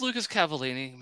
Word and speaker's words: Lucas Cavallini: Now, for some reason, I just realized, did I just say his name wrong? – I'm pretty sure you were correct. – Right? Yeah, it's Lucas Cavallini.